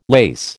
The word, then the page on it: lace: American English